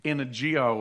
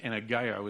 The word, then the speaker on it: energeo: There is a Greek word that goes in there, the word energio or energeo, or geo is actually pronounced, energeo is how it's pronounced.